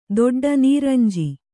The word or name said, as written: ದೊಡ್ಡ ನೀರಂಜಿ